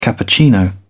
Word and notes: cappuccino: British English

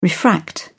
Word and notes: refract: British English

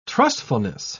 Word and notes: trustfulness: British English

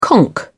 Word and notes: conk out: British English

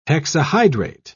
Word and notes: hexahydrate: British English

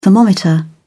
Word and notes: thermometer: British English

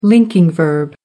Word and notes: linking verb: American English

